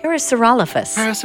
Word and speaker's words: Parasaurolophus: Parasaurolophus.